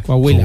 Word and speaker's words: Coahuila: Coahuila, Coahuila.